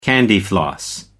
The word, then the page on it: candyfloss: American English